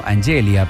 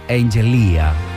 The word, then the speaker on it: Angelia: Él lo leyó inicialmente como Angelia, pero al elogiar su nombre, ella lo corrigió amablemente diciéndole que en realidad lo pronunciaba como Angelia.